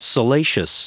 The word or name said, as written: salacious